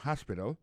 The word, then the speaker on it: hospital: L'hôpital, c'est comme ça, on dit ça en anglais, hospital. Alors, accent tombe sur premier syllabe, il y a trois syllabes, puis she likes to visit hospital. Ensuite, elle a écrit H-O-S-P-I-T-A-L, hospital, l'hôpital.